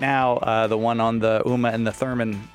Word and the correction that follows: Urim and Thummim: How do you say it? – The Urim and Thummim.